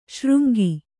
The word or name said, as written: ಸುರಂಗ